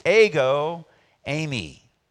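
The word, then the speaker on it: ego, eimi (ἐγώ εἰμι): They're pronounced ego, eimi. Like, let go my ego, right?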